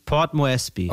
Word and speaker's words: Port Moresby: Moresby, Port Moresby.